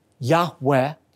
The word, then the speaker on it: Yahweh: In fact, when God revealed to Moses His personal covenant name, composed of the four Hebrew letters, Yud-Heh-Vav-Heh, oftentimes pronounced a breathy Yahweh, the tense of God's sacred name, Yud-Heh-Vav-Heh, carries with it the idea of continuous, unfinished action.